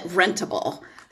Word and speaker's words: Rentable: You know, we pronounce it Rentable. So okay. Becoming Rentable. So it's the rent with a focus on the able.